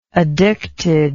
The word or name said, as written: addictedness